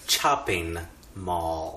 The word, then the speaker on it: Chopping Mall: Tonight's film is Chopping Mall. – Oh, I think you're mispronouncing Shopping Mall. – Chopping Mall. – Oh, because it's about robots with, like, they chop up people.